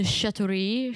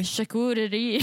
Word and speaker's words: charcuterie: The chatoury board. Charcuterie? – Charcuterie.